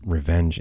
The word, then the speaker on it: RVNG: Sage with music from the Paradise Crick release on RVNG International, which somebody told me once that it's Revenge International, but I haven't heard them pronounce their label name yet.